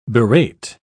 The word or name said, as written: berate